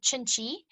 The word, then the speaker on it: Cinci: It's called Chinchi. It's spelled C-I-N-C-I.